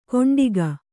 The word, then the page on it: ಕೊಂಡಿಗ: Kannada